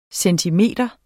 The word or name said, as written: centimeter